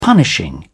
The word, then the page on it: punishing: British English